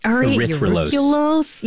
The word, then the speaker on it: erythrulose: Erythrulose.